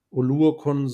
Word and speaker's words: Oluokun: Das ist ein talentierter Linebacker-Room mit einem gewissen Floor durch äh, Oluokun und Oluokun, so wird er ausgesprochen.